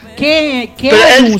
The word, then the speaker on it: Keanu: Keanu se pone. K-E-A-N-U. – Keanu Reeves. ¿Por qué es que suena la U? Suena U.